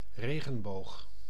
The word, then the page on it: regenboog: /ˈreɣə(n)box/; [ˈrexə(n)box]; /regənbox/